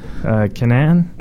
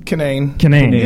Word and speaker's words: Kinane: I'm Keith Razor with Alan Lee and our guest today, Kyle Kinane. Did I say it right? – No. – It's Ken? – Kinane. Yeah, but yeah. That's not right.